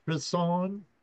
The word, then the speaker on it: Croissant: The Crisson, it's like a Crisson. – Croissant. – Is the proper way to say it. – Croissant. – And that's how the owner explained it to us, right, Jesse? – Yep.